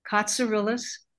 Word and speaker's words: Cotsirilos: Stephanie, Stephanie, Cotsirilos, Cotsirilos, LOS, all one word, no caps.